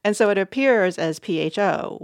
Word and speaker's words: pho: I still say pho. I'm not supposed to say that. I know it's wrong. So what should I call it? – Pho.